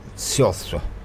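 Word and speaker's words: Saoirse: It's spelled like Shirshi. But it's actually pronounced Siostra. Ronan. Something like that, anyway, if you want to know how to pronounce it.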